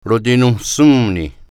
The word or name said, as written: rotinonsionni